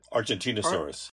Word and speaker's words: Argentinosaurus: Argentinosaurus.